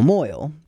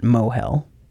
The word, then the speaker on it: Mohel: Ground zero for this rumor is the involvement of the so called Royal Moyle, to which the Algemeiner article refers. Unfortunately, this delightful little rhyme is wasted on Dr. Darby, who pronounces it Mohel, which is technically correct. It's the Hebrew pronunciation, but still.